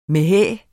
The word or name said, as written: mæhæ